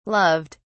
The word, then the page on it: loved: British English